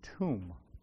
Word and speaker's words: tomb: And I always think of tomb, T-O-M, the B is silent.